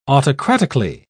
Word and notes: autocratically: British English

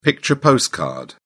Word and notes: picture postcard: British English

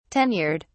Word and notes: tenured: British English

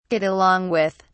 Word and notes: get along with: British English

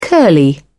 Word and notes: curly: British English

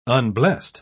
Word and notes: unblest: British English

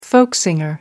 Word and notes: folk singer: British English

